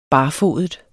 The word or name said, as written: barfodet